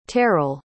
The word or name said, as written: terrill